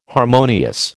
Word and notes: harmonious: American English

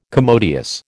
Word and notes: commodious: American English

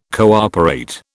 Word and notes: cooperate: British English

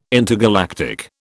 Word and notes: intergalactic: British English

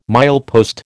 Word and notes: milepost: American English

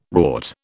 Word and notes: rort: British English

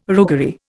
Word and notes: roguery: Indian

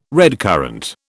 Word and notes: redcurrant: British English

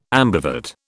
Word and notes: ambivert: British English

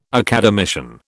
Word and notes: academician: British English

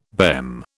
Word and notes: BEM: British English

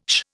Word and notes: sh: Indian

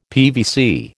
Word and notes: PVC: American English

